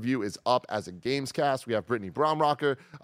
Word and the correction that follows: Brombacher: uh, Brombacher. I keep saying this wrong. Uh, Brittany Brombacher uh,